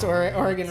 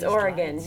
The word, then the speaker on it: Oregon: We're here with a special guest from Portland, Oregon, I always pronounce Oregon wrong, even though I have friends and family who live there. Well, how do you pronounce it? – It's Oregon. You got it right. – Oregon.